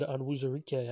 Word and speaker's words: Onwuzurike: From what I am hearing from NFL draft experts, what I'm hearing and seeing, Levi Onwuzurike. I can't say it right. How is it pronounced? You know, it sounded pretty good. Onwuzurike, I think.